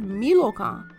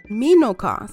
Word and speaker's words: Milokan: The word Milokan. And sometimes it's pronounced Minokan. So sometimes you may hear a Milokan with the L in the middle or a Minokan with the N in the middle.